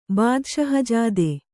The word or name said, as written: ಬಾದಷಹಜಾದೆ